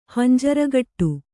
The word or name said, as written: ಹಂಜರಗಟ್ಟು